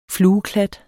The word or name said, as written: flueklat